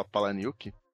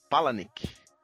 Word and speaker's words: Palahniuk: Na verdade é o Chuck, mas sabia que eu descobri hoje, inclusive, que não fala Palaniuk? Como é que fala? É Palanik.